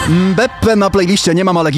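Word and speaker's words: Mbappé: Pomnieć o poprawnej wymowie tego nazwiska. – Mekambe, jak on? – Mbeppe.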